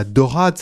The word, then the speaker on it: Daurade: Alors bon, les Toulousains et puis ceux qui passent à Toulouse finissent par ou connaître ou passer devant cette basilique de la Dorade. Ça s'écrit D-A-U-R-A-D-E.